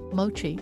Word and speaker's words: mochi: So she'll also have a book, and then she also has the movie coming out about making mochi. Is that how you say it? Mochi? – Yeah. And it's real mochi.